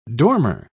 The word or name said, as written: dormer